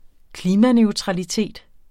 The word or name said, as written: klimaneutralitet